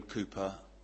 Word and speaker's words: Cowper: That portrait is of a man called William Cowper, or Cowper. It's actually pronounced, spelled C-O-W-P-E-R, but it's understood that that's how it's pronounced, Cowper, William Cowper.